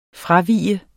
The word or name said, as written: fravige